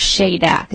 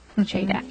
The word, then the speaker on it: SHADAC: And it's pronounced, the acronym is pronounced SHADAC. Is that correct? – SHADAC.